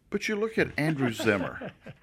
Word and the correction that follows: Zimmern: Yeah, Zimmern. – Zimmern.